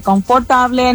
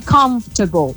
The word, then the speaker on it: comfortable: No se dice confortable, no se dice comfortable, nada de eso, se dice comfortable.